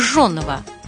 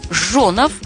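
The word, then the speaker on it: Жжёнов: А вот как правильно «Жонов», как я это произнесла, или «Жонов»? Ведь если следовать старшей норме, то надо говорить «Жонов». А почему же мы никогда не слышим такого произношения фамилии известного артиста?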